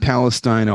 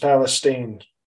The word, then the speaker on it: Palestine: First, let's just um, you, you visited East Palestine, Ohio. – It's pronounced Palestine.